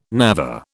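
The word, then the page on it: névé: British English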